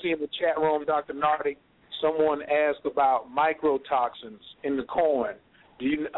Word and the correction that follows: mycotoxins: Yeah, they're not Mike. I don't think the person said it right. It's mycotoxins. Mycotoxins come from fungi, fungus.